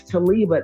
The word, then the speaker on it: Taliba: That's Taliba. It's spelled a little different. I'll spell it for you. T-A-L-I-B like baby, A-H.